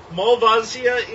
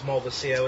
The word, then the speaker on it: Malvasia: Malvasia is the Italian pronunciation for this grape. – Oh, okay. – So it's not entirely wrong. I tend to go for the pronunciation that I was taught first, which is Malvasia, which apparently is more of the Greek and Northern Italy pronunciation in Spain, Spanish pronunciation.